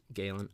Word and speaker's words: Galyn: I'm going to be breaking down what it was like growing up with my name, Galen, which is spelled G-A-L-Y-N, but pronounced Galen.